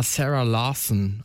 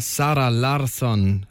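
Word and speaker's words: Zara Larsson: Alle sagen mal Sarah Larsson, aber es ist eine Schwedin. Also eher Sarah Larsson, Lush Life.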